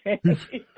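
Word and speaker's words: Fani: I have heard recently the pronunciation is Fanny, but that was never the pronunciation until you started doing your bit on the Fanny.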